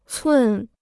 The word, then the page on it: 寸: cùn